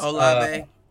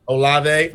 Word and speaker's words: Olave: Chris Olave. – Olave.